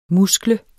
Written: muskle

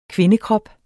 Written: kvindekrop

